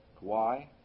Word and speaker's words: Kauai: How do you pronounce that? Kauai. Kauai. There are Filipinos, Koreans, Japanese, and Don is supported by Southern Baptists.